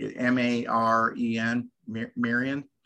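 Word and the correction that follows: Maren: Marin. – Yeah. There you go.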